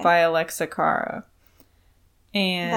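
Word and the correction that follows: Alessia: that's not how you say her name. – Okay, I'm sorry. What is it? – It's like Alicia.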